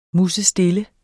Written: musestille

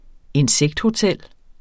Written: insekthotel

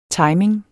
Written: timing

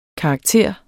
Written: karakter

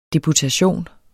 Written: deputation

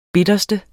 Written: bitterste

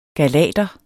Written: galater

